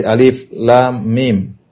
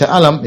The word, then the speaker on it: alif, lam, mim: Misalnya alif, lam, mim, ya. Maka dibacanya per huruf. Alif, ya, kemudian lam, kemudian mim. Ya, tidak dibaca, ya. Tidak dibaca alam, ya. Enggak dibaca alam, tapi dia dibaca per huruf.